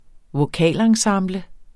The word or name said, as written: vokalensemble